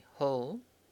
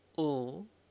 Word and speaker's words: hal: This pronoun, in fact, has several different pronunciations depending on the speaker. Some say hul, some say ul, some say l, and some say n. We'll use the spelling H-A-L, hul, here in this book, but you should be aware of these other pronunciations and know that they are all correct.